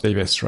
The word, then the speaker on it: Davies: It is generally true that the Welsh surname that looks like Davies in letters is pronounced Davis, right, in general? – Yeah, in Wales.